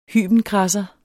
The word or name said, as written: hybenkradser